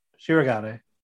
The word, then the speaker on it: Shirogane: Kaguya overheard Shigaran talking about... – Why are you saying Shirogan? – Yeah, it's his name. Shirogane. – Shirogane. You got to say it properly, goddammit.